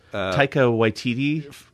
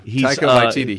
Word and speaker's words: Taika Waititi: Taika Waititi. – Thank you. – Taika Waititi.